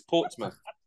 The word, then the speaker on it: Portsmouth: I will say it is spelled Portsmouth. – What? Portsmouth? What? – It's Portsmouth. – Say it again slowly. – Portsmouth. – Portsmouth. Okay, got it.